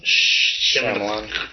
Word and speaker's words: Shyamalan: Shyamalan.